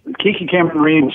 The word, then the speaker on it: Camarena: Yeah, it's Kiki Camarena station.